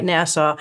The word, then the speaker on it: Nassau: We want to get that pronunciation right, Nassau, and parts of eastern Queens how it's going is it's actually pretty exciting.